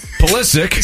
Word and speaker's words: Pulisic: We're hammering Christian Pulisic. – Christian Pasoli. – Pulisic. – So mad he's mispronouncing the name.